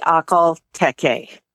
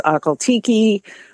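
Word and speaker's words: Akhal-Teke: It does depend on your accent. Okay. Most people in the United States say Akhal-Tekes. But I've heard it pronounced many different ways. Akhal-Tekes. Over in Russia, they add a lot of spit to it.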